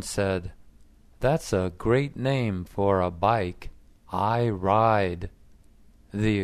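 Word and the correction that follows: Iride: exhibitor said, "It's pronounced Iriday, not I ride. Iriday is Italian for iris."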